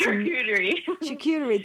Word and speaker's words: charcuterie: how did you say it? Charcuterie, or how did they say it? – That's charcuterie.